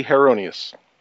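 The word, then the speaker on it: Heironeous: I am a paladin, first time playing one, of with the following the deity Heronius. – He's in time. – I think that's pronounced right. – Yep. Close enough.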